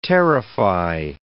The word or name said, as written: terrify